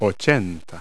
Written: eighty

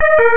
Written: lava

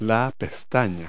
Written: eyelash

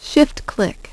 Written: Shift-click

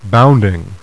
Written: bounding

